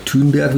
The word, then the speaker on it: Thunberg: ich glaube, glaub, Greta Thunberg oder Thunberg, wie sie richtig ausgesprochen wird, die ist ja im Moment gerade so, wie Sie sagen, das ist ja so ein Hype.